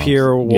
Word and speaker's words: Sapir-Whorf: Sapir-Whorf.